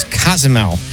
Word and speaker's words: Cozumel: Kazumel? – Yep. Like, actually, oh my God. – The correct pronunciation is Cozumel.